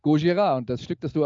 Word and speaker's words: Gojira: Ähm, Wikipedia sagt mir, Gojira sei der japanische Originaltitel des Films Godzilla und die Aussprache Gojira, ich habe so ausgesprochen, wie die Bands auf der Bühne selbst ausspricht.